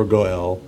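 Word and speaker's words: Gaal (גאל): But in Hebrew, the verb for redeem, it's pronounced as Gaal or Goel.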